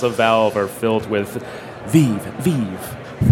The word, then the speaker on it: Vive: It is pronounced the Vive, and I only say that because I've heard people from Valve pronounce it that way.